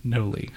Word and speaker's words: Noli: Noli. – How do you spell it? – N-O-L-I.